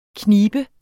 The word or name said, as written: knibe